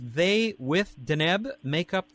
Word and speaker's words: Deneb: Deneb. At least that's how you told me it's pronounced.